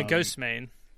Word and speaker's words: Ghostemane: Yeah, Ghost Main.